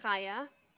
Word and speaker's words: Chaya: My name is Chaya. – How do you spell that? S-Y? – So C-H-A-Y-A. – C-H-A-Y-A.